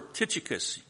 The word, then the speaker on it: Tychicus: When I send Artemis or Tychicus, now you pronounce that name however you want to, but that's how I pronounce it.